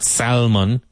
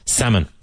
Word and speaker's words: salmon: It's not salmon. Ah, salmon. The L is silent.